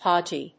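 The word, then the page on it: podgy: podg・y; pάdʒi (en-us); pˈɔdʒi (uk)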